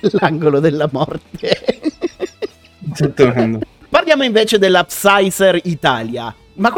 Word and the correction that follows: Pfizer: Mm, Pfizer. Che muta come Django. Pfizer.